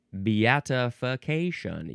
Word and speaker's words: beatification: Beatification.